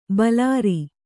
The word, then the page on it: ಭಲಾರಿ: bhalāri